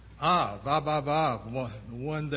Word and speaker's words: Av: The Hebrew word for father is spelled A-V in English. And it's pronounced something like Av, Av, Av, Av, Av.